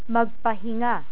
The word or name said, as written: magpahinga